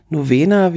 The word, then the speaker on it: Novena: Deswegen zählt die also nicht als die höchste Passstraße der nufenen oder Novena, wie er auf Italienisch ausgesprochen wird, mm, ist tatsächlich die größte Passstraße, die in der Schweiz beginnt und in der Schweiz auch endet.